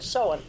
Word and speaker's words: Samhain: So we'll get that, you know, we can pronounce it Samhain.